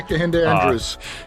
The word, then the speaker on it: Kehinde Andrews: Watched is it Dr. Kehinde? I'm probably saying his name wrong. – Yeah, Kehinde Andrews. – Kehinde Andrews, yes.